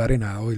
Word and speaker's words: Arenado: Así que bueno, terminamos eh, Arenado, el caso de Arenado, y lo dije bien.